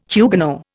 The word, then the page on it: Huguenot: Indian